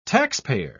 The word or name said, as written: taxpayer